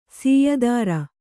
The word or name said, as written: ಸಯ್ತಿರ್